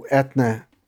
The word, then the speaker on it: Eithne: It's just how, well, I mean, that's how Etna, E-I-T-H-N-E, her given name, is pronounced.